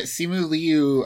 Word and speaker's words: Simu Liu: The actor, the actor's name, Simu Liu, right? – Yeah, Simu Liu. Simu Liu. That's how I've heard it pronounced, like Simu Liu.